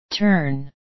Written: turn

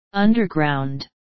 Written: underground

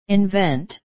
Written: invent

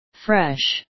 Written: fresh

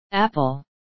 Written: apple

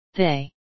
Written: they

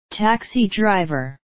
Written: taxi driver

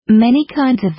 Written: many kinds of

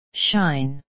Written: shine

